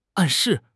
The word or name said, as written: 暗示